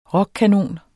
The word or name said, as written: rockkanon